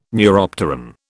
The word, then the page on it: neuropteran: British English